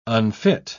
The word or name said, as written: unfit